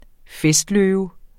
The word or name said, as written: festløve